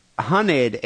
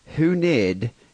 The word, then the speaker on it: hunid: Hunted, H-U-N-I-D, it's not pronounced hooned, it's hunted.